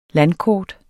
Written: landkort

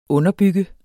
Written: UNDERBYGGER

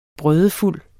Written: brødefuld

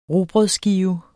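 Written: rugbrødsskive

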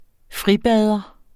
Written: FRIBADER

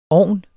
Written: ovn

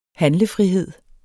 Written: handlefrihed

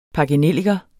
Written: pakkenelliker

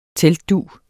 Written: teltdug

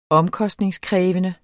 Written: omkostningskrævende

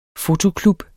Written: fotoklub

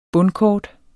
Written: bundkort

